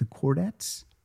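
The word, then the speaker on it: Chordettes: This is by the is it the Cordettes? Is that how you say it? – That sounds right.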